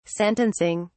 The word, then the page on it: sentencing: British English